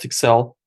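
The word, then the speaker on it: Tkxel: It's a company called Tixel. It's based out of Pakistan. – Can you spell it? – Yes, it's a T-K-X-E-L.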